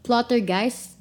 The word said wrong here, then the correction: Poltergeist: Did I say that right? – Dude, I don't know how to say stuff. – Plottergeist. Poltergeist. – Poltergeist. I just moved one letter.